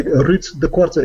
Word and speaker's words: Ruud De Korte: By the way, Ruud De Korte is my name, and that's why I chose Space Machine Imagery as my artist name, because nobody can pronounce my name properly because there's too much rolling R's in it, and it's Dutch.